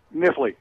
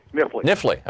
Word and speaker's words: Knifley: Knifley, Kentucky. Is that how it's said? – Knifley. – Knifley.